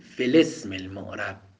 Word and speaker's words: في الاسم: همزه اعراب همزه قطعه، چون باب افعاله، لذا تلفظ میشه، میگیم الاعراب. اما همزه اسم همزه وصله، باید بیفته، میگیم فلسم المعرب.